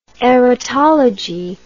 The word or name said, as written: erotological